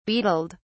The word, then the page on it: beetled: British English